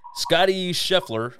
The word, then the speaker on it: Scottie Scheffler: Scottie Scheffler, I believe, was the name.